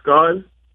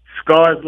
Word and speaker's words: Scars: Yeah, my name is Scars. – How do you spell that? – Scars, like, you know, when you get cut up.